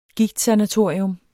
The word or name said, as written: gigtsanatorium